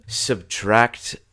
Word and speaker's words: SBTRKT: Subtract is another artist that I find and I follow myself. That's a fantastic influence. It's spelled S-B-T-R-K-T. It subtracts all the vowels from the word subtract.